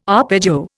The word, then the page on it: arpeggio: Indian